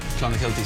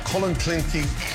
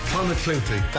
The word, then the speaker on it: Clonakilty: Elsewhere, a clip has gone viral of Gordon Ramsay cooking with Niall Horan and he struggles to pronounce a famous Irish sausage. – If I move to this country, it's something that I've always latched on to. Irish tea bags or Clonakilty sausages. From Cork. – Clonakilty. – Clonakilty. – Clonakilty, thank you. – He can cook them, but he can't pronounce them.